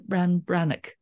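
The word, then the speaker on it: Breathnach: It's called Simple Abundance, A Day Book of Comfort and Joy by Sarah Ban Brennick. It's spelt Breathnack.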